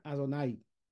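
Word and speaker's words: Adonai (אדני): Nosotros atraemos el influjo divino a través de el nombre Alef, Dalet Nun Yud que se pronuncia Adonai.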